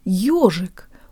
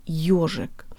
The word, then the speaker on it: ёжик: The first syllable is a diphthong that consists of a Y sound and an O sound. Ё, ё. We have a Y sound in English, as in the word yes, but the Y sound is much stronger at the beginning of the word ёжик.